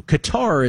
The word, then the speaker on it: Qatar: Qatar is how Americans decide to pronounce Qatar, but Cotter is actually closer to the actual Arabic.